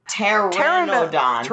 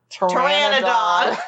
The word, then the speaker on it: Pteranodon: Yeah, he's a that P is silent. – Oh, it is. – Like so, pterodactyl. – Pterodonadon. There's only one D, so I gave it an extra one. – Yeah. – Pteranodon.